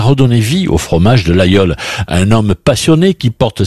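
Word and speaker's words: Laguiole: Ça s'écrit l'Aguiol, mais on prononce l'Aïeul.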